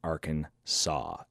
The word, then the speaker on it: Arkansas: The explorers met a group of Native Americans and they called them Arkansas, S-A-W. Since the letter S at the end of the French words is usually silent, we pronounce the home state Arkansas, and that's the reason why.